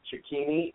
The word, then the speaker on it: Cecchini: Moving down to AA, there's Gavin Cecchini, I think it's pronounced. – I believe that's correct.